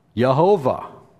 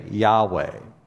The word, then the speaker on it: Yahweh: But it's almost certainly not the right way to pronounce it. In fact, we have many other early Jewish writings that tell us that the early Jewish people in the temple or in their synagogues would say Yahweh.